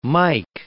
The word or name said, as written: mic